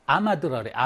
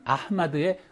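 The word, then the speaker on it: 아흐마드에 (أحمد): 기자들이 뭐라 하냐면 아마드라래, 아마드. 아마드는 아흐마드에 흐가 있는데 흐가 H 발음 보통 언어에서 잘 발음 안 되거든요.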